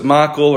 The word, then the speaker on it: Michal: But the Bible says, so David said to Michal, or some people pronounce it Michael or Michal or Michal. For the sake of today, I'll probably go say Michal. I'll probably say Michael next time.